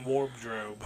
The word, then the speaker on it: wardrobe: I tried to pronounce the B in wardrobe.